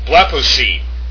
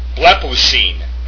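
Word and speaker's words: Blepousine (βλέπουσιν): The next form, blepousine. Blepousine. That's how you pronounce that.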